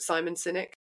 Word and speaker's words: Sinek: I don't want to quote Simon Sinek. That's how you say his name.